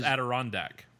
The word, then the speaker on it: Adirondack: It's pronounced Adirondack.